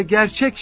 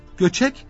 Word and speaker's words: Göçek: İşte göçek kelimesi kaf, vav, ç, k ile yazılıyor. Vav harfi öyle yazılmış ki r gibi okunmuş ve gerçek şeklinde telaffuz edilmiş. Aslı göçek Davut Ağa'dır.